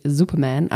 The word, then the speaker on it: Superman: Hier haben wir das ähm, Duschgel für Superhelden und da haben wir natürlich Superman. Also die Betonung liegt auf Man, also ist ein Mann.